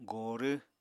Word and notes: gorw: Apatani

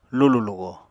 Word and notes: lulù-lugo: Apatani

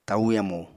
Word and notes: tau yamu: apatani

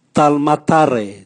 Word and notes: talmatare: Raga

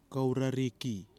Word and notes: KOURARIKI: kwamera

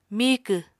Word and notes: miikw: Apatani

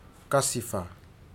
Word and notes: kasifa: futuna aniwa

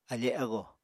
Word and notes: afe ago: Apatani

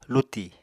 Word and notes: luti: Apatani

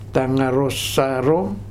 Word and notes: Tagarosaro: Futuna

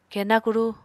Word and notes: kena kuru: Apatani

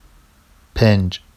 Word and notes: penʤ: Vafsi

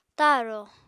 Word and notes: ta7rò: apatani

